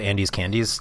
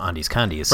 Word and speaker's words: Andes Candies: That is a joke because obviously it's called Andes Candies. The one thing I've ever disagreed with Jean on is her pronunciation of these. – Yes. – As she calls them Andes Condies.